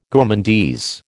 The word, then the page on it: gourmandise: American English